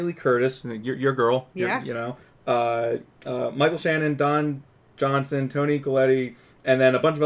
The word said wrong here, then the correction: Collette: Let me tell you, and Tony Collette, she is one of my most favorite actresses.